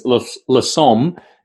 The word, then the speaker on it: Lissome: And to be clear for listeners, La Somme is spelled LISSOME.